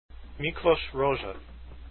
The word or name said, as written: rozsa